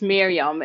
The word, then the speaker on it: Mirjam: But before I do, I need to address your name and your pronunciation, because as you read it, it's written M-I-R-J-A-M. And I know we don't call you Mirjam. – Yeah, no, in Dutch, it's Miriam. It's because the J in Dutch is like silent.